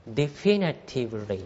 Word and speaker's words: definitively: The vast majority of studies support a link、まあ、大多数の研究は関連性をサポートしています、ね、関連性のあることを証明しています、はい、 Granted, no study can definitively、これ読み方気をつけてくださいね。 definitively と言います。